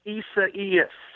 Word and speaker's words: Isaias: Dan, I heard that on a broadcast this weekend, so that's a fairly easy pronunciation for Isaias. If you say Buenos dias first, then you can, Isaias kind of just flows off your tongue.